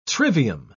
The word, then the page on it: trivium: British English